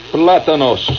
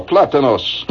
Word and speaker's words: plátanos: How do you pronounce it? Plantons. Plantains. I'm getting the official quote here from Sanssouci. Yeah? Platanos. Gracias, senor. Platanos. Very good.